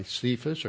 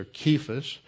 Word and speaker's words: Kephas: He rose from the dead on the third day, and then he was seen by Cephas, or Kephas, actually is the way you would pronounce that, and that's another name, that's the Aramaic name for Peter.